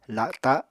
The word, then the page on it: la7ta7: Apatani